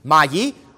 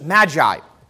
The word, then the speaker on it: magi: Now, the Greek word that is translated here as wise men, in Greek it's magi, or as it is commonly pronounced in English, magi.